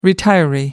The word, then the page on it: retiree: American English